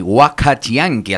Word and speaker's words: Huacachianqui: Escuchamos Chicos Descalzos, por las voces quichuas, y Huacachianqui, ¿así bien pronunciado, Chigo? – Así es, muy bien, me hizo llorar.